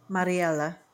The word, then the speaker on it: Mariella: The two main Lorenzo and, say her name again. – Mariella. – Mariella. Okay, yes, Mariella. I like how you say it because it's like an Italian accent.